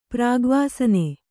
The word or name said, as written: ಪ್ರಾಗ್ವಾಸನೆ